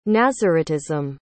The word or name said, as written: naziritism